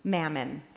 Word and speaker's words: mammon: Older translations of this text, and maybe this is a familiar phrase for some of you, you cannot serve both God, and I know I'm going to be corrected if I say this word wrong, mammon. Did I say it right, Jane? Okay, good.